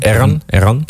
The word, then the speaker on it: Eran: Ja, wij openen de tweede set met eh, Evan, haar Evan. Zeg ik dat goed zo? Even haar even. – Eran Eran haar even.